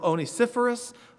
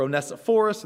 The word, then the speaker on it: Onesiphorus: He says, the Lord grant mercy to the house of Onesiphorus, or Onesiphorus. That name is pronounced a number of ways, but grant mercy to his house.